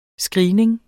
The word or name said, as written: screening